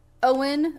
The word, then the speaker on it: Eoin: The next person I think we need to mention is Owen Mackin. He's Irish. It's spelled E-O-I-N.